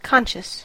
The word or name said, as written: conscious